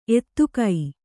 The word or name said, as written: ಎತ್ತಿಗ